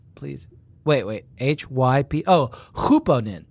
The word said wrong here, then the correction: hyponym: That's okay. Sorry. No, it's pronounced hyponym, but hupo means over.